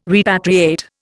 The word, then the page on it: repatriate: Indian